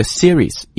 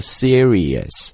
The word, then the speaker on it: series: 但在这个地方我们要讲一下发音啊，虽然呢我们的这个暴虐程度版当中会带大家一个一个练啊，但是可能没有购买同学我们也提一提，就是这个 series 一定不要把它弄混成了 serious。很多中国同学在读这个 series 的时候会读成这个 serious 是严肃的意思 ,OK, 而这个 series 它表示的意思是系列的，主要的是在第二个元音的这个组合的时候 ,series 是个单音啊，而 serious 它是一个 es, 所以是一个双音啊，所以在这里大家要做一个区分。